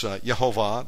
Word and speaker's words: Yehovah: And the King James had it right, Jehovah, spelled with a J. But it's Yehovah, that's how you pronounce it.